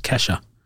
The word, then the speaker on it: Kesha: So apparently that she's pronounced Kesha.